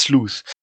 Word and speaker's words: Sleuth: Wir reden heute über Digimon Story Cyber Sloth. – Sleuth, Star Trek Prodigy Sleuth. – Okay. Ich glaube, es heißt Sleuth. Es wird Sleuth ausgesprochen.